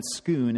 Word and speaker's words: Scone: This is the stone of scone. Scoon is the pronunciation.